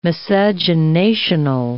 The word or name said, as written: miscegenational